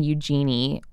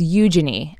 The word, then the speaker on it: Eugenie: So we've been saying Eugenie, which I think is honestly understandable for an American because it's spelled like Eugene. – It's spelled like Eugene. Eugene is a man's name that's pronounced Eugene. So I would think that adding IE on the end of it would make it Eugenie. – Yeah, no. – But no, it's Eugenie.